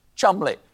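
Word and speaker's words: Cholmondeley: I'm sorry, I'm being told it's actually pronounced Chumley.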